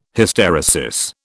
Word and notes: Hysteresis: British English

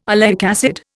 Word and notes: oleic acid: Indian

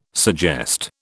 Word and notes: suggest: British English

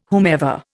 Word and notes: whomever: Indian

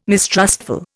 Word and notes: mistrustful: Indian

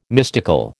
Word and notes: mystical: American English

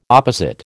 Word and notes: opposite: American English

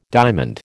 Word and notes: diamond: American English

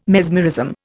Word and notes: mesmerism: Indian

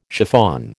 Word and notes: chiffon: American English

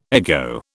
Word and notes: ego: British English

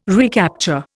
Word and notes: recapture: Indian

